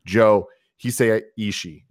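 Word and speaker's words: Joe Hisaishi: Once again, the music by Joe Hisei Ishii is how you properly say his name. I keep messing that up.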